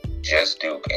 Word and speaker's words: Juzt Duke: My social media, my Facebook is Darius, that's D-A-R-I-U-S, Juzt Duke, and Juzt Duke is spelled J-U-Z-T-D-U-K-E, not J-U-S-T.